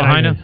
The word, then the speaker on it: Lahaina: Well, we stayed in Lahaina. Is that how you say it? – Lahaina. – Lahaina.